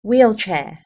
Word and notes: wheelchair: British English

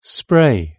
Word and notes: spray: British English

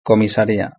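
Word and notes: la comisaria: Spanish (Spain)

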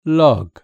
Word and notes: log: British English

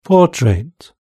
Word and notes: portrait: British English

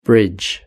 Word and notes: bridge: British English

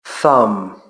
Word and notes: thumb: British English

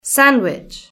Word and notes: sandwich: British English

